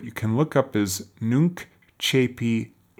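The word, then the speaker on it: Nunc Coepi: And again, Nuncepi is spelled N-U-N-C, and then the second word is C-O-E. P-I. That's pronounced Nuncepi.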